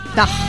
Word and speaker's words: DÀH!: Da. – Da es, como si fuera pues, andaluz.